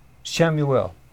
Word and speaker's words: Chamuel: C-H-A-M-U-E-L. How do you pronounce that, Michelle? Samuel.